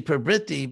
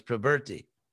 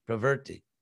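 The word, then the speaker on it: pravṛtti: I won't go into all, you know, the very long list of, of similar words, but actually, you know, we say prabriti because uh, I'm just pronouncing it the way everybody does, but the real Sanskrit pronunciation is pravrti, pravrti, and nivrti.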